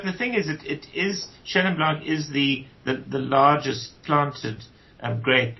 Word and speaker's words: Chenin Blanc: Well, just in general terms, Ian wasn't being disparaging about Chenin Blanc or Chenin Blanc, as the French would pronounce it.